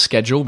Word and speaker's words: schedule: So this, folks, this word is spelled S-C-H-E-D-U-L-E. S-C-H-E-D-U-L-E. And it's like a timetable. – Yeah. – Right? – I know how you say it in British English because I've had this discussion with many people. I still pronounce it schedule because mm-hmm. I don't know. Maybe I was used to working at Apple with Americans, yeah. – But the British way is schedule.